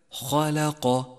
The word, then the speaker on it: خلق: اقرأ كلمة خلق خلق، اقرأها بشكل صحيح ولاحظ صفات الحروف التي فيها، فالخاء حرف مفخم، واللام حرف مرقق، والقاف حرف مفخم، فحاذر من الخلط بين الصفات واقرأها بشكل سليم هكذا خلق خلق.